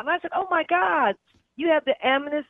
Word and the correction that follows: Amethyst: I'm not pronouncing it right because I'm talking too fast. – Amethyst? – He was like, yeah, Amnes.